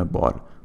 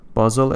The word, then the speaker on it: بازل: بهش میگن کنوانسیون بال بازل اسم شهری تو سوئیس و تا جایی که شنیدم تو گویش های اروپایی و انگلیسی این حرف ز تلفظ میشه